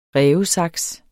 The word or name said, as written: rævesaks